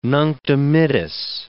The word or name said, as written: Nunc Dimittis